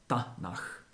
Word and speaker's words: Tanach: Tanach. Je to vlastně výslovnost jakoby zkratky, třípísmené T, N a K. Ale ty písmena se čtou Ta, Na, K. A z toho vzniklo to Tanach.